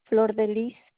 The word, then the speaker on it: Flor de Liz: Flor de Lis Serna. – Can you spell that for me, please? – F-L-O-R-D-E-L-I-Z.